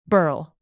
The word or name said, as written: burl